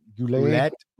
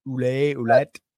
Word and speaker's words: Ouellette: Henrik Lundqvist, Mike Vernon, Tom Barrasso, Pierre Turgeon, Caroline, is it Ouellette? – Ouellette. – I just said it wrong.